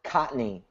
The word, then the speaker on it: Cotney: It's like the first N is silent. – Cotney. – So, yeah. K O T N Y. You could get by pronouncing it. Though some people also pronounce the N. My family pronounces it both ways.